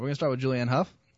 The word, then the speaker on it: Hough: Hough rhymes with tough.